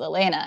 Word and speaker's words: Elena: Although one time I realized when I was like 20 that both of my parents call me Elena, like they do the hard E, and I refer to myself as Elena. And I was like, have I been mispronouncing my own name for like 2 decades? – You're like, what's my name?